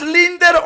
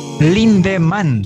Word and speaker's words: Lindemann: Vos con el Limberman, ¿es Linder o Limber? – Lindemann. ¿Quién eres? Lindemann. – Dejemos en Doberman y listo, ¿no? No puedo pronunciar. – Lindemann. – ¿Cómo? – Lindemann. – No es Linder.